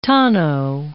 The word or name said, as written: tonneau